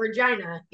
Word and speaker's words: Regina: You know how I feel about Regina. It's the city that rhymes with fuck.